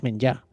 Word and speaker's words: menjar: Me ha dicho esta mañana la persona que me ha dicho lo de menjar, la R no se dice, es menjar.